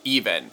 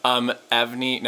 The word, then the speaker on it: EVNNE: Because it's spelled E-V-N-N-E. So I say Evnie. – Okay. – Evnie. No, fuck. Even, formerly Blit.